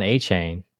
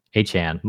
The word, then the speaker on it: Achane: Devon Achane. And I said that wrong. Achane, my bad, buddy.